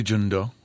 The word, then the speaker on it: Jeong-do: I say Jeong-do. – Do, yeah. Okay. Do, like Play-Doh.